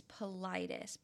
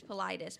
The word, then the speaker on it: Paulides: David it's Politis, but it's I listened to him say it so that I didn't come in here and butcher it because I'm always butchering people's names. But it's Politis. But it's you and me both. – It's spelled like Paulides, and that's why.